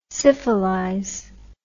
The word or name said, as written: syphilize